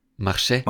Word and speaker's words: marcher: Marcher. – Man schreibt es mit, am Ende mit ER. Aber das R, das spricht man nicht, man macht marcher.